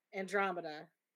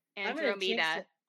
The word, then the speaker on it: Andromeda: I am going to jinx Andromeda Tonks. Did I say it right? – Yeah. – Andromeda.